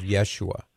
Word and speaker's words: Yeshua: Jesus, the word Jesus, as we say it in English, is just an anglicization of the name that Jesus has, which is pronounced something along the lines of Yeshua.